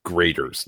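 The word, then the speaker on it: Graeter's: So, when I was in college, I used to go to an ice cream shop, and the ice cream shop was called Graters. Now, it's not, let me spell it. It's Graeters. Graters ice cream is actually from Cincinnati, Ohio.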